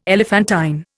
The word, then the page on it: elephantine: Indian